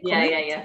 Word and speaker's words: Komoot: Komoot.